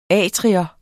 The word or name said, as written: atrium